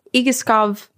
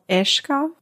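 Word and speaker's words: Egeskov: So I am going to begin us with telling you about a place called Igaskov Castle, which is the way that I butcher it as an English speaker. But I believe after listening to about 100 videos of Danish people pronouncing it, it's called Eshkov Castle.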